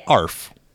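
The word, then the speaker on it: ARFF: I believe it's called an ARF. – Yes. ARF. – Like, sounds like a dog barking.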